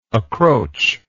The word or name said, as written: accroachment